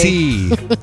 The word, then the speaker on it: Boise: Boise.